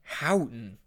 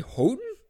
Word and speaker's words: Houghton: Anyway, I was like, he was like, yeah, we went to this town called Houghton. And I was like, you mean Houghton? Because up there, it's basically Canada. Like, that's how it's pronounced.